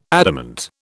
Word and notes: adamant: British English

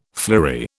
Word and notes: flory: British English

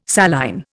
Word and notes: saline: Indian

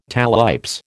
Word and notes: talipes: American English

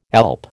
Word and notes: alp: American English

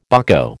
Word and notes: bucko: American English

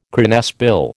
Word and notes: cranesbill: American English

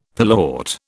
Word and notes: pilewort: British English

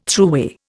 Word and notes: throughway: Indian

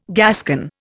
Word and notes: Gascon: Indian

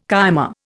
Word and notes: cyma: Indian

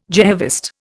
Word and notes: Jehovist: Indian

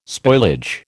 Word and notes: spoilage: American English

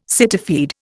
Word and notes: cityfied: Indian

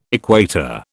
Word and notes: equator: British English

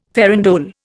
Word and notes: farandole: Indian

